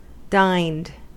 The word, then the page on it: dined: American English